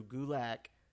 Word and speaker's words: Gulak: I need to make sure not say Gulak because his Twitter says it rhymes with attack. So it's Drew Gulak. – It rhymes with attack, not with Glock.